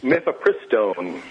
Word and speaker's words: mifepristone: Mifepristone.